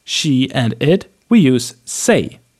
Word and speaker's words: sig: It is written S-I-G, but not pronounced like sig, but sig.